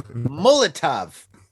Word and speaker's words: Molotov: It's like mullet and a little bit of top. Molotov.